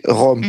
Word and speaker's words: Rom: So it is this second R, which is at the beginning of the name of our people, Rom. We say Rom.